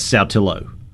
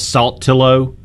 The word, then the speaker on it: Saltillo: That's Saltillo. People screw that one up all the time. – I get corrected. – Salt. They call it Saltillo. They add that T in there somehow that makes it weird.